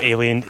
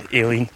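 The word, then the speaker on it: Eilean: Fiach Éilean, which is not alien, Éilean, yep. Which is Gaelic for island.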